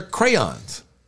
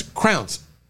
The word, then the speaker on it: crayons: But she pronounced it as crowns. Mm. I was like crown. Like, I thought you were saying crowns. She goes, well, it's like that, crowns. – No, it's not. – It's not like that at all.